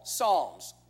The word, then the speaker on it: Psalms: Many of his psalms have been preserved for us in this wonderful book we call Psalms, with the silent P if you've ever wondered.